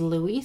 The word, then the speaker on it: Louis: I had previously said Louis Wayne, but in the movie, they pronounce it as Louis, so that's what I'm going to go with.